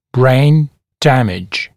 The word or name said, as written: brain damage